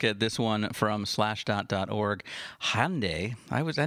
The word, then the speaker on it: Hyundai: According to the internet, it is pronounced Hyundai. – Hyundai.